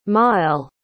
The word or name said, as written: maël